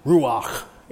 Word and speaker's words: ruach: And they had fun saying the word ruach because it's not ruach, it's not ruach, it's ruach.